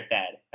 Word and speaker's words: Thad: I was a Thad fan. He's not my favorite. Do you guys say Tad or Thad? How do you say it? – I've been saying Thad because I think of Thaddeus.